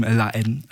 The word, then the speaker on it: La Haine: Den französischen Film La Haine empfohlen. Mhm. Habe ich richtig ausgesprochen? Ja. Das Haar ordentlich weggelassen, so wie es in der französischen Sprache.